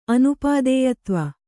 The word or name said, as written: ಅನುಪಾದೇಯತ್ವ